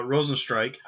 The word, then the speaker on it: Rozenstruik: Um, it actually uh, the main event was Ciryl Gane um, uh, versus uh, Jairzinho uh, Rozenstruik. I actually pronounced it the right way for once.